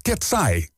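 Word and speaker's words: Katsey: Want je schrijft K-A-T-S-E, Griekse I-E, en dat spreek je uit als Ketsai.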